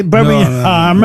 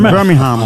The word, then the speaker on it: Birmingham: Voilà, on ne prononce pas le G, Birmingham. – Birmingham.